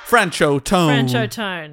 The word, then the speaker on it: Franchot Tone: How do you say your name? How to pronounce Franchet Tone? Here we go. – Franchot Tone. That sounds good.